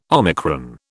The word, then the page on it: omicron: British English